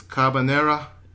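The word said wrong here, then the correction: Kabaneri: I think it's Cabaneri.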